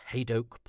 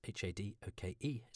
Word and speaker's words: Hadoke: Uh, these are on Twitter at Hadoke Podcasts. H A D O K E is how you spell my surname.